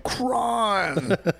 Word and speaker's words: Cron: And Cron is Cron. – But the O has the little line over it. – It's called long O.